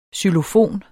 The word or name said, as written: xylofon